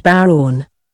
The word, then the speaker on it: bodhrán: The bodhrán, pronounced Bowron.